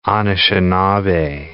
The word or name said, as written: Anishinabe